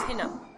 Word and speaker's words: Hina: It's Hina. – Hina Agarwal. – Exactly. And it's spelled H-I-N-A. A lot of people just spell it like H-E-E-N-A, which is not the case.